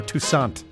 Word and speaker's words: Toussaint: Because on his early recordings, it's Al T O U S A N. Like a phonetic spelling. Like, in other words, you don't say Toussaint or Toussaint or Toussaint. Hence my pronouncing it Toussaint.